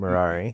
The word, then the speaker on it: Merari: Merari? It's like Ferrari, but Merari.